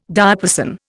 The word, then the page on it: diapason: Indian